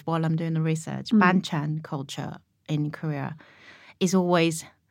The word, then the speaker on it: banchan: Let's talk a bit about, how do I pronounce this? Banchan? Banchan. – Perfect.